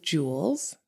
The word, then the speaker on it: Jewells: And you just have to use the code Jewells.